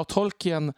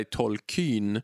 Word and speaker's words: Tolkien: Och då säger Tolkien själv jo men det ska uttalas som om man stavade Keen, alltså K-E-E-N. – Som ivrig alltså? – Precis, mm. exakt. Och sen eh, framgår det då lite senare i en fotnot i den, den version av den artikeln som jag har läst eh, att han, han liksom säger att ja, Tolkien uttalar själv sitt namn så här. Och så är det tydligt att han menar också att betoningen ska ligga på andra stavelsen.